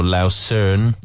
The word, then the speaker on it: Lausanne: There's one in Lausanne or Lausern, as they said in the German pronunciation.